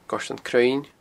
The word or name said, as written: Goirtean Cruinn